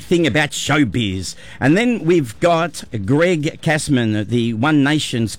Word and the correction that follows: Cheeseman: I think pronounced Cheeseman. – Cheeseman, sorry. Cheeseman, yeah.